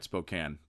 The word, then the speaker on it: Spokane: Oh, right, Spokane, but that's not how it's spelled. It's funny to trip people up who don't know that.